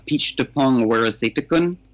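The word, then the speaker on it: Apichatpong Weerasethakul: It's got some stuff from Edward Yang, and I just learned how to pronounce the name, Peach Tepong Werasetakun. I would have never said that before had I not heard Martin Scorsese say it on the Blu-ray.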